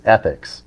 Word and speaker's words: ETHCX: So we managed to work with a grower, a cultivator in Las Vegas, and their brand name is Ethics, which I love. It's spelled E-T-H-C-X.